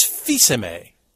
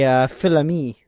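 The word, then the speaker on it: Fils-Aimé: It's not Phil's Amy. It's Fisime. Remember that. – I don't think we've ever said that right. – No, before. We always say Philami.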